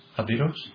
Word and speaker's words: Jivaros: J-I-V-A-R-O-S. How would you pronounce that? Would you know? Or somebody? J-I-V-A-R-O-S. – Aviros? – Okay. – Spelling it? – J-I-V-A-R-O-S.